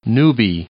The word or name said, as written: newbie